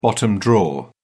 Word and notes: bottom drawer: British English

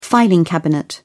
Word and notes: filing cabinet: British English

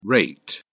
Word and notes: reit: British English

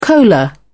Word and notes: cola: British English

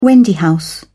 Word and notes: wendy house: British English

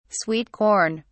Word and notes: sweet corn: British English

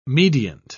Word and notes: mediant: British English

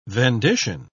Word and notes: vendition: British English

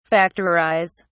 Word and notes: factorise: British English

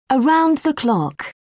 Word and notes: around the clock: British English

